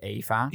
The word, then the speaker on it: Ewa: Om het met mij mee te gaan in het verhaal van Ewa. Ewa, zeg ik dan. Wisniewska. – Ja. – Ewa is dus Eva met een W. Dat spreek je gewoon uit als Eva.